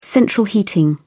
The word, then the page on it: central heating: British English